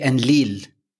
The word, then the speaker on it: Enlil: So usually when you hear about, or you read it like Enki and Enlil, however, it's actually Enlil. There's a very heavy accent on the il, and this shows in a lot of other places.